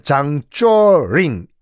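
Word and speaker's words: 장쩌링인데 (張作霖): 당시 만주를 지배하고 만주는 중국 땅이지만 만주를 지배하고 야 여기는 내 군대가 지배하는 여기 내 땅이야 내가 어 왕이야라고 하고 있던 사람이 장작림이란 사람이었어요. 중국 발음은 장쩌링인데 장제스와 라이벌이었습니다.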